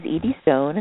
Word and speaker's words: Edie Stone: My name is Edie Stone, and that's spelled E-D-I-E-S-T-O-N-E.